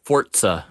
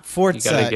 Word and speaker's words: Forza: I believe it's pronounced Forza. – Forza, yes, that's true. I have definitely heard enough people say Forza.